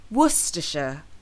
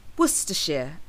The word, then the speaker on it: Worcestershire: So they often end up saying Worcestershire or Worcestershire. However, it's actually pronounced Worcestershire or Worcestershire, as in, can you pass me that bottle of Worcestershire sauce, please?